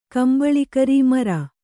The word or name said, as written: ಕಂಬಳಿಕರೀ ಮರ